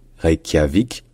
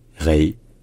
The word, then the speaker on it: Reykjavik: En revanche, en français, on adapte ça et on dit ça en trois syllabes. Reykjavik. Reykjavik. Reykjavik. En fait, la principale différence, c'est est-ce que c'est un OU long ou est-ce que c'est un OU court. Franchement, les deux sont acceptés.